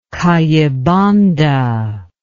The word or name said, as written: kayibanda